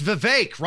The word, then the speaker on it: Vivek: It's Vivek, rhymes with cake. – Okay. – Vivek rhymes with cake. That's how you say it.